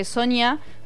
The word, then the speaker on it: Sonja: Como que Sonia, por si la quieren googlear, se escribe Sonja. Sonja Glass, porque es alemana. – Ahí va. – Pero se dice Sonia, en todas las entrevistas le decían Sonia.